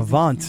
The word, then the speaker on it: Avant: Avant. – Yeah. – What if I would have given you the maybe, George, if you would have said it rhymes with savant,